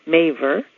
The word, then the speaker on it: Maver: My last name, Maver, rhymes with favor and savor, as in, do me a favor and open that Sally Maver book so I can savor it a little longer.